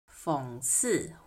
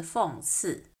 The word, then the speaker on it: 讽刺: 讽刺你会听到有人把它读成三声，有些人则是读成四声。讽刺或是讽刺，毕竟所有的研究都是从一个很小的点开始的嘛。